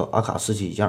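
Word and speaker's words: AK-47: 这里边比较有名的就是老毛子的 T 三式坦克，嗯，也有叫特三式的哈，就像是这 AK 四七叫阿卡四七一样。实际上呢，这个是一个俄式的发音哈，咱们就管叫 T 三式吧。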